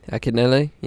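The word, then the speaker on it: Akinyele: Then Neek the Exotic, Akinele. How do I say his name? Akinele? – Akinele, yeah.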